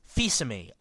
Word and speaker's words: Fils-Aimé: There's this one interview that he did with somebody, and they asked him how to pronounce his name, and he said it's pronounced Fils-Aimé, a nice French name, like that.